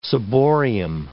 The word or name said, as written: ciborium